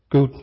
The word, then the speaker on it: Gould: The biologist and atheist Stephen Jay Gould, was it Gould? Alison, how do you say his surname? Gould, thank you.